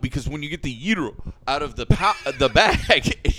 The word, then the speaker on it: gyro: Say it right. Go on. – Gyro. – Nope. – Gyro. – There you go. – Gyro sandwiches.